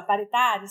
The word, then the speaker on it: paritário: É só a gente ver se a gente fizer esse pariativo, né? Variado, vamos variar. Paritário, né? Como é que fala? Paritário. Se a gente fizesse isso aí, bora fazer.